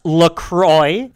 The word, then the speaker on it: LaCroix: LaCroix, and it is pronounced LaCroix. It is now pronounced LaCroix or LaCrosse or LaCroix. None of those things, although it did originate in the town of La Crosse, Wisconsin.